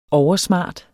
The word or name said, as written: oversmart